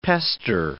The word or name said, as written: pester